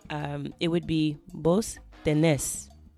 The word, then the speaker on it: tenés: So instead of tienes, it's vos tenés. So tú tienes, vos tenés.